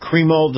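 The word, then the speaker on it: Crimo: Robert Bobby, I believe you pronounce that, Cremo the third. I've heard it pronounced a couple different ways tonight. He's 22 years of age. You want to say something? – No, I think you're correct on the pronunciation.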